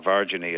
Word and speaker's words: Virginia: And places like Trim and further north, even South Cavan, in the town of Virginia, or Virginie, as it's pronounced up that part of the world,